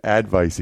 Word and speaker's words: advice: I almost did advice again. What is going on? That's 2 days in a row where instead of saying advice, I said advice. I'm putting the wrong emphasis on the wrong syllable.